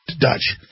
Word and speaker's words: Deutch: How do you pronounce his name? Deutsch? Is that Dutch? D-E-U-T-C-H? Yeah, Dutch.